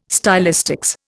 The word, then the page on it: stylistics: Indian